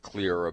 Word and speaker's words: Xlear: And Clear is spelled differently. It's X-L-E-A-R. And that X, it's because where it came from.